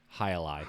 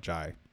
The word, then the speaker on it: Hialeah: How do you say it again? – Hialeah. – Hialeah. Okay, I used to call it Jai.